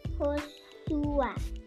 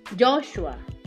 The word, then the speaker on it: Joshua: Ahora yo te pregunto a ti, ¿cómo se dice Josué en inglés? Um, Josué. No. Joshua. Joshua. Joshua. Muy bien.